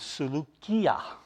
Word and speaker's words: Seleucia: But remember, the names of these towns, the names of these places and that, is in Greek. In Greek, the way you would pronounce that is Seleucia. There's a, what we call a kappa there, and it's, so it's a hard K, not a soft K.